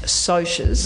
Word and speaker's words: Socs: Now, Sox, that's spelled S-O-C-S, and it's short for socials. And Cohen and I both know now that it's meant to be pronounced socias. – No. – But no. – We, as I'm sure most first-time readers, second-time readers and so on, pronounce it in our heads as socks, and so socks it will be tonight too.